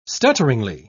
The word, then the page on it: stutteringly: British English